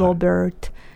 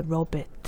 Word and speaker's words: Robert: Yeah, Robert Johnson. So the American pronunciation then r is more pronounced. – Hi, hi. – Robert. So and uh, British pronunciation, there's no air, so Robert.